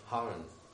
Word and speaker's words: Haran: And he went to Sharon. – I poszedł do Sharon. – Yeah, Haran.